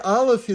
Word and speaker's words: Aleph: The letter Aleph is silent.